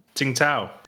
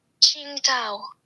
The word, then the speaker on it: Tsingtao: And it's spelt T-S-I-N-G-T-A-O. But that's not... How do you pronounce it? – Tsingtao. – No, it's actually... Hold on. I'll get the official YouTube... our YouTube friend here to pronounce it. Wait for it. – Tsingtao. – Oh, Tsingtao. – Did you hear that? It's a Tsingtao, yeah, which is interesting.